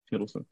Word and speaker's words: Hiddleston: Hiddleston, right.